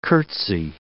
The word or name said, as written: curtsy